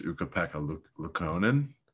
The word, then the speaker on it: Ukko-Pekka Luukkonen: I think it's Ukko-Pekka Luukkonen. I guess him. I gotta look it up. Uko, UKKO, Pekka, Luukkonen. Yeah, I did say it right.